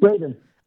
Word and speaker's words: Raiden: Raiden.